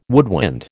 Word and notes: woodwind: American English

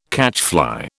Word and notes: catchfly: British English

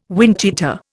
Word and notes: windcheater: Indian

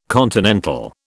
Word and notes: continental: British English

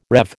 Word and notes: rev: American English

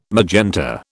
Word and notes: magenta: British English

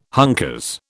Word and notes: hunkers: British English

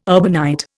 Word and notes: urbanite: Indian

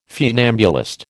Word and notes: funambulist: American English

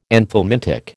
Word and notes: anthelmintic: American English

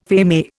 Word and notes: feme: Indian

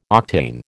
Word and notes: octane: American English

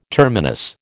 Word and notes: terminus: American English

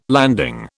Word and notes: landing: British English

